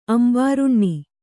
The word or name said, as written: ಅಂಬಾರುಣ್ಣಿ